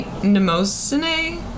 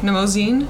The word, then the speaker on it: Mnemosyne: I have no idea how to pronounce that. – Can you say it one more time? – I say Mnemosyne. – Okay. – Mnemosyne.